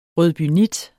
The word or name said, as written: rødbynit